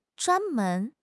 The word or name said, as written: zhuan1 men2